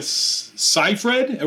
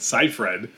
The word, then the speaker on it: Seyfried: Amanda Seyfried, are we saying that right? Seyfried.